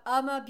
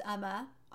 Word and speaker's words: UOMA: Okay, next up we have Uma Beauty. It's spelled U-O-M-A, but it is pronounced Uma. It says it on their website.